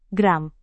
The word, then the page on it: gram: Indonesian